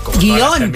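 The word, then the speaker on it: guion: Guión, para mí. Sí. – Sí. Pues no lleva tilde. No, porque aunque muchas personas la pronuncian en dos sílabas, la RAE considera que es una palabra monosílaba.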